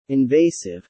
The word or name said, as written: invasive